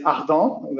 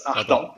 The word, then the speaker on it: Ardant: In French, we say ardent, but it's ardent. It's French. Okay. It's hard in English.